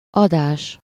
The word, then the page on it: émission: [e.mi.sjɔ̃]; France